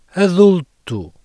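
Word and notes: adulto: European Portuguese

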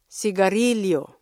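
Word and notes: sigarilyo: Filipino

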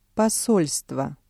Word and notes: посольство: Russian

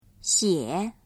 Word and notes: 写: xiě

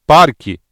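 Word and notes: parque: Brazilian Portuguese

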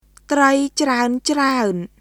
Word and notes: ត្រីច្រើនៗ: トライ・チラウン・チラウン; trəi craən craən

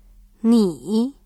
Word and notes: 你: nǐ

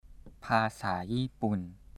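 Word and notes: ภาษาญี่ปุ่น: Thai

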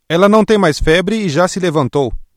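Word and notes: levantar-se: Brazilian Portuguese

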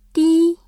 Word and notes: 低: dī

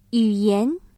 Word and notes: 语言: yǔyán

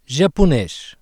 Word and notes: japonês: ポルトガルポルトガル語

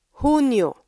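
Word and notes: Hunyo: Filipino

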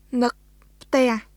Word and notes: នឹកផ្ទះ: ヌック・プテアハ; nɯk pʰtɛ̀əh

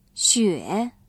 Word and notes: 雪: xuě

